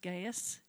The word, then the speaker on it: Gaius: I think you pronounce it Gaius, G-A-I-U-S,